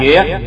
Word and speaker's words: thuyết: Phật thuyết, chữ thuyết là sướng duyệt sở hoài. Chữ thuyết này thời xưa cũng đọc là duyệt.